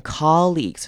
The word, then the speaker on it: colleagues: 首先看它开头的 O，这边要念成 R 的声音，短母音 O 的声音。很多人会误念成长母音 O，念成 co，但不对，因为那会念 co 是另外一个字，同事 coworker。那这边 colleagues 是短母音 O 的声音，念 R， colleagues。